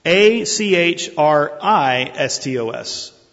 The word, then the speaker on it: achristos: The word Christless is Achrestos, A C H R I S T O S, a letter difference. Useless, Achrestos. Christless, Achristos, and they would be pronounced exactly the same. Different spelling, same pronunciation.